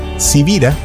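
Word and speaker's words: Sibira: Civira, que es mi apellido y que no es tan común, se escribe con S de Samuel y con B de Victoria.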